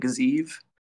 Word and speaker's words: Gaziev: Jarzio Rozenstruik took on Shamil Gaziev, I think they were pronouncing it Gaziev. – Sounds about right.